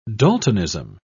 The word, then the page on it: daltonism: British English